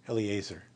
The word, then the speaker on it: Eliezer: Eliezer.